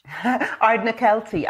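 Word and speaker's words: Ardnakelty: Can you pronounce the name of the village? – Ardna Kelty. I made it up.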